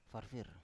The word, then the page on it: farfir: Biak